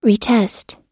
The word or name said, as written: retest